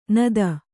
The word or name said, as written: ನತಿ